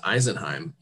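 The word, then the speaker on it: Isenheim: And he's leading a 6-part Lenten series using the, what I discovered should be pronounced Eisenheim altarpiece, located in France.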